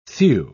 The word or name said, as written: thew